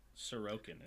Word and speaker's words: Sorokin: I have it. Sorokin is how you say it.